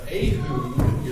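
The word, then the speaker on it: Ehud: And as we read there in verse 1, when Ahud, is the way that's pronounced, was dead, the children of Israel did evil in the sight of the Lord.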